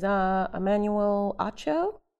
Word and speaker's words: Emmanuel Acho: I think his name is Emmanuel Acho, is how you say it.